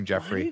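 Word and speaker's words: Geoffrey: Joffrey. – Which I said Joffrey, but everyone told me it's not. It's not Joffrey. – Yeah, I looked up the pronunciation video for it. It's definitely that.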